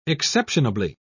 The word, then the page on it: exceptionably: British English